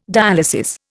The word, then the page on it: dialysis: Indian